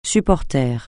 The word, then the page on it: supporter: francais